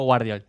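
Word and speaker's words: Gvardiol: Gravidiol, ¿lo pronuncié bien? – Cosco Guardiol.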